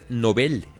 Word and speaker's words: Nobel: Que dicen que se dice Nobel. – Sí, ¿okay? Alfred Nobel. En realidad. – Dicen que se dice así, que la Academia Española dice que, Nobel, que no digamos Nobel, que Nobel. Digamos Nobel.